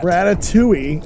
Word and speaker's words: Ratatouille: Ratatouille.